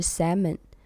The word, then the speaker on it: salmon: salmon，然后他说 salmon L 不发音，那是 salmon。